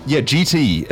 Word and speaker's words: Geetee: GT, a really good band. If you want to check them out, it's spelled GEETEE.